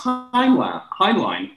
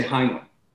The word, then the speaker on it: Heinlein: And you know, the way you pronounce Heinlein is the German way, rather Einstein or even Eich, you know, at the verb. But in America, we say Heinlein, because that's what I'd like to talk about next.